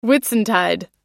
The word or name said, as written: whitsuntide